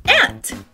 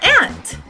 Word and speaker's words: aunt: A palavra tia em inglês se pronuncia aunt. É isso mesmo. É a mesma pronúncia, aunt.